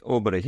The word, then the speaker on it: Åberg: Well, because if you write down Aberg as it's pronounced, it looks like Aubrey. So Ludwig Aubrey.